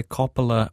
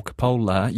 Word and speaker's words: Coppola: So let's start with the pronunciation, Coppola. – Thank you. – I did actually double check this when I heard you promoting this segment earlier on. I thought, oh, I better check. And it is Coppola.